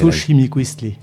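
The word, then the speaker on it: xochimiquiztli: » C'est très difficile à prononcer. – Xochimilquistli. – Oula, Xochimilquistli. La prestigieuse mort fleurie, celle qui lui vaudra l'envié destin post-mortem de compagnon de l'aigle, compagnon du soleil.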